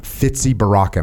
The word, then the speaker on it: Fizi Baraka: Fizi Baraka. Am I saying that right? – Fizi. Fizi. Fizi. So it's just like it's written Fizi Baraka.